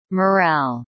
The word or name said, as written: morale